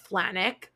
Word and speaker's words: Flajnik: And my personal first season was Ben, I think his last name was just pronounced Flanick, but it's spelled F-L-A-J-N-I-K.